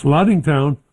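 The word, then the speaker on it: Lattingtown: L-A-T-T-I-N-G town. – That's Laddingtown.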